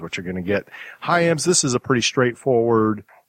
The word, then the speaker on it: Hyams: This one is obviously the sequel, different director, Peter Hyams.